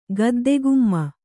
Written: ಗದ್ದೆಗುಮ್ಮ

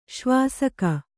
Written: ಶಿವಸುಖ

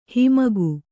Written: ಹಮ್ಮುಗೆ

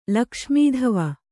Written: ಲಕ್ಷ್ಮೀಧವ

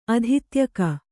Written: ಅಧಿತ್ಯಕೆ

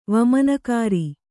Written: ವಾಮನಾಕಾರ

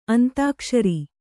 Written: ಅಂತಾಕ್ಷರಿ

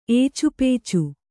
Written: ಏಚುಪೇಚು

